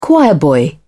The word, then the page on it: choirboy: British English